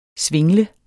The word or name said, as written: svingle